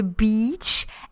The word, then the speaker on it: beach: Now in English, when I speak English, you know, we live next to the beach, and I always make sure that I make a long, like deliberately long E. Because I'm worried about saying, you know, the other word with a short E.